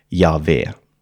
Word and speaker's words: Jahve: Så då är frågan då, hur ska man egentligen uttala Guds namn? Och de flesta moderna bibelforskare är eniga i att det antagligen ska uttalas ungefär Jahve. Det är lite komplicerat för skandinaviska munnar att uttala, men någonting i den stilen.